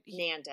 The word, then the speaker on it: Nanda: Nanda NANDA rhymes with panda, or spelled like panda. But check him out.